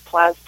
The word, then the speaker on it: Plastek: So if they just go to Indiegogo dot com, and then they search Plastek,